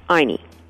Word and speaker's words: Ayni: We are using a Quechua word, and it's spelled A-Y-N-I, Aini.